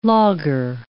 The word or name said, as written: logger